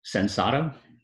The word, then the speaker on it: Sensato: How do you say the name of your business? – Sensato. – Sensato. – So, yep. Yeah. It means simple in Spanish.